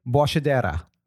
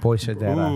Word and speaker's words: Boixadera: I've had normally people say Boixadera. That's not correct. It's Boixadera. – Boixadera. – Ooh. Boixadera.